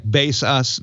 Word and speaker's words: Baseus: Now, it's spelled like base us, but that's the way they pronounce it.